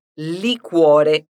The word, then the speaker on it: liquore: E atenção também à palavra liquore. Na escrita, ela é representada pelo Q-U-O, sendo que o U é pronunciado aqui. Então, liquore.